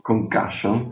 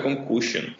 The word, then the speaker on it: concussion: Esatto, questo famoso concussion, pronuncio bene pure sì, mi, mi risulta. – Vedi, io dicevo sempre concussion. non si smette mai di imparare.